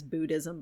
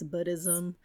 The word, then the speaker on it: Buddhism: I talk about everybody says Buddhism, but it's Buddhism. It's okay. Pronounced like bud.